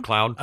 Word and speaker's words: MacLeod: Now, Colonel, how do you say this name, Adam? Is it MacLeod? Is it MacLeod? – MacLeod. – Okay. – Yeah. – I couldn't remember how you pronounced it, but that was certainly not how I read it. And, you know, I'm showing my Southern stupidity here. I can't, you know, pronounce these Scottish names.